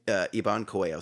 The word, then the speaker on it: Iban Coello: Iban Coelho. So I-B-A-N-C-O-E-L-L-O.